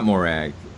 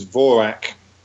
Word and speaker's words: Morag: I mean, we would pronounce it Morag, and Morag is now a fairly familiar Scottish girl's name, actually, but the correct Gaelic pronunciation is Vorak.